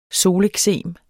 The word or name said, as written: soleksem